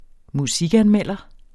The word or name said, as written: musikanmelder